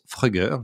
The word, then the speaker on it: Fruggr: Alors, ça s'écrit F-R-U-G-G-R, mais ça se prononce Frugger, je leur ai demandé.